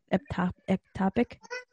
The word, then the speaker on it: ectopic: if like tubular, how did you say it? Ectopic? Did I say it right? – Yes. She said yes.